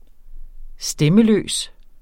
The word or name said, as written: stemmeløs